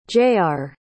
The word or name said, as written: jayar